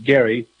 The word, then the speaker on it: Gerry: It goes back to Elbridge Gerry, spelled Gerry, but it's pronounced Gerry.